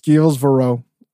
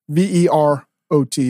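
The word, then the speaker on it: Gilles Vérot: It's Gilles Varot. – He's the he's the fucking, he's the goat of charcuterie. Verot.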